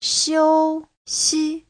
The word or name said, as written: xiu1xi5